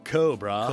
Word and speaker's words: Cobrah: And that's spelled C-O-B-R-A-H, like Cobrah. – Cobrah.